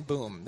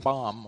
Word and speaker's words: Boom: In America, we say her name this way, Corrie ten Boom. They would say ten bomb over there, but boom or bomb, doesn't matter.